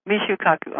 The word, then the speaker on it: Michio Kaku: Michio Kaku, I'm sorry.